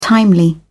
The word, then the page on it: timely: British English